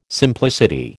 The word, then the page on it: simplicity: American English